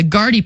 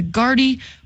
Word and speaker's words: Gaerte: The Gardy Party is coming to Memorial this fall. It's spelled, I think, G-A-E-R-T-E. And so I keep telling everybody, just think party. It's the Gardy Party.